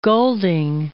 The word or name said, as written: Golding